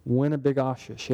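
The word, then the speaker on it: Winnibigoshish: It's Lake Winnebogoshish. Have you heard of that lake? Make sure I'm saying that right. Winnebogoshish. Yeah, that's it.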